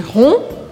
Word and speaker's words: rond: Se você for mais redondinho ou redondinha, je suis rond Je suis ronde, sem pronunciar o D no masculino.